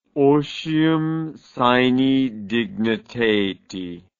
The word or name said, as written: otium sine dignitate